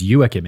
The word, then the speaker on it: Joachim: Okay, he's German, so I guess it's Joachim in German. Okay, look, I'm gonna try my best, okay? The Wimp. Joachim